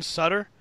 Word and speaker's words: Sutter: Stuttered, is that how you say his name? – Braxton Sutter. – Sutter, yeah. – Yeah.